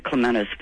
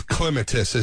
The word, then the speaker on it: clematis: And I keep saying clematis, but you're saying clematis. – It's clematis is the actual pronunciation.